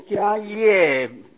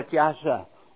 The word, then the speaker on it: 迦叶: 这新一家呀不用这个叶字，他用这个慈悲摄受的那个摄字啊，所以翻作迦摄波，这里用迦叶啊迦波，所以这个叶啊就不能念作叶，念作摄，摄跟这个慈悲摄受的摄同音啊，就是这个摩诃迦摄也要念作迦摄。